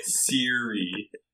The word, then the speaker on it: Siri: Did you just call it Surrey? – That's what it's called. – Bro, it's spelled S-I-R-I, Siri. – It's Siri.